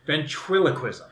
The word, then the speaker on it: Ventriloquism: Let me try that again. It's called Ventriloquism. I didn't say that right the second time. I'm going to say it the third time. Ventriloquism. There it is.